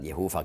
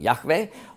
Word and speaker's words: Yahweh: Aber wenn man eben wissen möchte, wie hat man das korrekt ausgesprochen, und sagen Yahweh. Und warum wissen wir das so genau? Weil es Umschriften gibt mit griechischen Buchstaben bis in die frühen Jahrhunderten unseres Zeitalters ab Christi Geburt. Und da wurde das umgeschrieben mit zum Beispiel J, also Jota, Alpha, Beta und Epsilon. Und das Beta weich ausgesprochen gibt dann Yahweh, Yahweh, Yahweh.